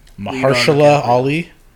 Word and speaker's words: Mahershala Ali: I'll try it. Mahershala Ali. – Nice. – Did I get it? – That's it. Oh.